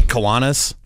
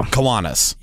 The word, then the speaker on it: Kiwanis: Kiwana, how do you say that? Do you know how to say that? – Yeah, it's not the way you're saying it. – Okay, Kiwanis. – There you go.